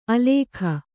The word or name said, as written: ಆಲೇಖ